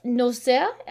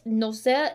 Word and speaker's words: Nozière: And she did actually win the Cannes Award for Best Actress for two films, Violette Nozière. I may be pronouncing that wrong. Violette Nozière, N-O-Z-I-E-R-E. – I think you were pretty correct the first time.